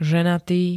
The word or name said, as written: ženatý